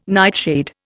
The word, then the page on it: nightshade: Indian